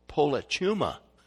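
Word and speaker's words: Polychuma (πολίτευμα): Let me say it correctly. Polychuma. Polychuma is the Greek word.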